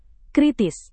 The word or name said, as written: kritis